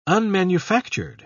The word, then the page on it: unmanufactured: British English